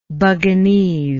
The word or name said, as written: buginese